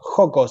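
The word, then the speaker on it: Jocko: Esto, Joko Willink, el, autor, Joko, se escribe con J si lo quieren buscar, J-O-C-K-O, J-O-C-K-O, Joko Willink, W-I-L-L-I-N-K, W-I-L-L-I-N-K.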